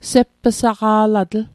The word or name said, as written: sepp'saqa'latl